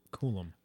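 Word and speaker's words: Coulombe: It's C-O-U-L-O-M-B-E. Coolum. – I was about to miss that B. – Coolumbi. Yeah. – Coolum, right? – Don't freeze him. Coolum.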